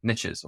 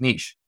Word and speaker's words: niche: It's pronounced niche. Well, then why have I in my entire life always ever heard it pronounced niche? – Me too.